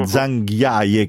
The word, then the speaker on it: Zhangjiajie: Zhangjiajie, credo che si pronunci.